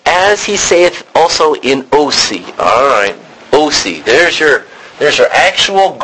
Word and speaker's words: Hosea: So, a Greek guy would pronounce it OC. But this is Hosea.